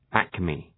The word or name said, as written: acme